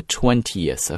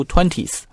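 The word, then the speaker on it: twentieth: 那么在什么时候啊 ,by the 20th of March, 在这里我们稍微的去讲一讲这个第20 啊就是 20后面加一个 th, 我们应该怎么读啊，不要读 20th, 啊是 20th, 要加一个 e 这个声音 ,20th of March, OK?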